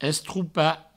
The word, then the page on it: estropar: Provençal; estroupa